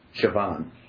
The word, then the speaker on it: Siobhan: S-I-O-B-A-H-N. – I don't know how to pronounce that one, but B-H-A-N, I would pronounce Siobhan. – Yeah, B-H-A-N, I don't know what I said. Say it again. – Siobhan.